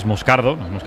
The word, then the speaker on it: Moscardo: Como bien ha pronunciado Unai, es Moscardo, es brasileño, sí, sí.